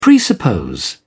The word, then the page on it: presuppose: British English